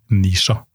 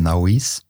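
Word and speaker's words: Naoise: Og husk, det uttales Nisha, for dere som eh, lurte på det. Ikke Naois? Ikke Naois.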